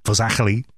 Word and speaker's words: Fazakerley: Pronounced in Liverpool, Fazakerley.